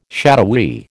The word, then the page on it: shadowy: American English